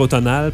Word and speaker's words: automnale: Il y en a qui disent automnale, mais je pense qu'officiellement, il faut dire automnale parce que le M n'est pas prononcé dans automne.